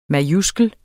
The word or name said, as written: majuskel